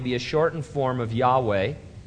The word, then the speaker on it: Yahweh: They do not like the pronunciation Jehovah. They consider it an erroneous and disrespectful Christianized version of the true biblical name of God. Still others say the pronunciation was never lost and that Yahweh is the correct pronunciation.